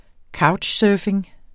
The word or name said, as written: couchsurfing